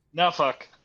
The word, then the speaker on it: Norfolk: I asked the cabbie when I was there, because he kept saying Nuffuck, and I was like, so is it Norfolk or Nuffuck? And he's like, Nuffuck.